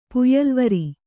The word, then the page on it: ಪುಯ್ಯಲ್ವರಿ: Kannada